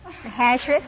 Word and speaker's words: Ahasuerus: Ahasuerus.